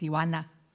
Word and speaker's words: Tijuana: Tijuana is pronounced Tijuana.